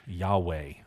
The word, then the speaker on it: Yahweh: And then if you want to put in parentheses, you can put the way we spell it, YHWH, Yahweh. That's how we typically say it, YHWH.